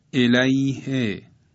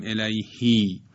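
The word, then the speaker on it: إليهِ: یا مثلا الیه، الیه، نمیگیم الیهی یا علیه و نمیگوییم علیهی.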